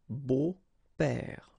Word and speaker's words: beaux-pères: Des beaux-pères, okay? Remember the S, but then it's silent.